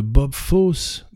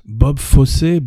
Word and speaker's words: Bob Fosse: Parlons un peu de Bob Fosse, Bob Fossé, Bob fossy c'est comme vous voulez, euh, effectivement, plusieurs prononciations,